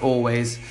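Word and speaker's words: Alvvays: It's spelt A-L-V-V-A-W-S, but it's pronounced Always.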